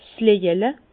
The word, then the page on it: 's Löjele: Bas-Rhin; Herrlisheim